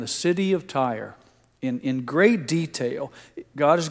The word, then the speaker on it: Tyre: Because of the similarity to our last name, I was hoping I could find some justification for pronouncing it Tyre. But it really is pronounced Tyre. It comes from the Hebrew word tsor, T-S-O-R, we would spell it.